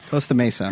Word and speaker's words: Costa Mesa: Costa Mesa.